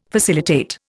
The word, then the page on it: facilitate: Indian